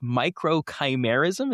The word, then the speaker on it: microchimerism: The first question, and this is to prove how much of a layman I am on this topic, how exactly do we pronounce microchimerism? Is that correct? – Microchimerism. So you were really, really close.